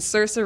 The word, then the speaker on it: Saoirse: Okay, we've got Cate Blanchett for Carol, Brie Larson for Room, Jennifer Lawrence for Joy, Charlotte Rampling for 45 Years, and Saoirse Ronan.